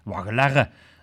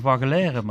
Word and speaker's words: Waggelerre: En eh, de optocht van eh, eh, voeren bij de Waggelerre. – Waggelerre, zeg ik het goed, Stijn? Ja, Dat is prima zo. Ja, normaal zeg ik Waggeleren, maar ik ben niet van schaven voeren, maar ik moet Waggeleren zeggen, zesten.